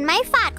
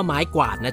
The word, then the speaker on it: ไม้กวาด: ใช่ค่ะเหมือนไม้ฝาดของแม่มดน้อยไงครับต้องพูดว่าไม้กวาดนะจ๊ะออกเสียงตัวครวบกล้ำให้ชัดๆว่าไม้กวาดไม่ใช่ไม้ฝาด